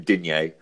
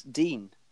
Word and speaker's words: Digne: Although, Andy, I've heard it's Dean, actually. – It's like a ny on the end, which is barely audible. So either is correct when you translate it into English, because we don't have that sound.